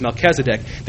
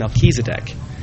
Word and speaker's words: Melchizedek: The Catholic and Mormon traditions pronounce that name differently, so if I start alternating pronunciations, you know where that's coming from. The LDS tradition refers to that figure from Genesis as Melchizedek. The Catholic tradition calls him Melchizedek.